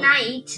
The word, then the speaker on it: knight: Remember that I said one knight. One it's pronounced knight.